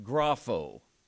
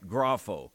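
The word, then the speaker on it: grapho (γράφω): As a matter of fact, when he says, I write, it's a verb, first person singular, that is pronounced grapho. I write, grapho.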